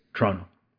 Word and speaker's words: Toronto: We don't pronounce the second T. It's Toronto. – Oh, it's T-R-O-N-O.